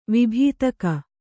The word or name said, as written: ವಿಭೀತಕ